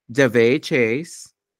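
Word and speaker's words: Daveigh Chase: Kihiro, voiced with real enthusiasm by Davey Chase.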